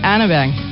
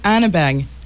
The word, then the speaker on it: Hardeberg: Mais c'est pas prononcé comme ça en Norvège, n'est-ce pas? – Non, c'est Ingrid Hardeberg. – Il va falloir que tu me répètes ça sans fond. Là. – Ingrid Hardeberg.